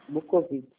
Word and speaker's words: Bukowitz: Soletrei certo? Eu falei certo? – Não. – Não, é ótimo. Como é que fala o seu nome? – Bukowitz. – Bukowitz. Ah, eu errei o W, né?